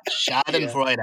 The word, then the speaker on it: Schadenfreude: Schadenfreude. Um, Schadenfreude.